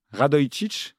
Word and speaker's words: Radojčić: Il n'y a pas de Z. Hein. Radojčić, si tu peux le dire comme ça.